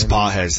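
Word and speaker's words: Pages: I think it's Páez. That's how you say it.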